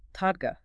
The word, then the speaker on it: Todgha: T-O-D-G-H-A. – Todga.